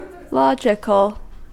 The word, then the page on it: logical: American English